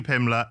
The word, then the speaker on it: Pimblett: So I'm We are, if you could already tell, we are with MMA fighter and UFC superstar, Paddy Pimlet.